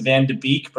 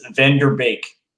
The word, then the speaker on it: Van de Beek: So a lot of people are going to pronounce it Van de Beek, but I think the correct way to pronounce it is Van der Beek.